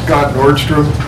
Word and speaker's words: Scott Nordstrom: My name is Scott Nordstrom. Scott, S-C-O-T-T, Nordstrom, N-O-R-D-S-T-R-O-M.